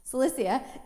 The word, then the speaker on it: Cilicia: They were Jews from Cyrene, Alexandria, Cilicia, thank you. I said that right at home, but not here, of course. Cilicia, in the province of Asia.